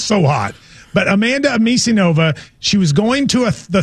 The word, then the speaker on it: Anisimova: Anisimova. – Anisimova. – How do you say it? – Anisimova.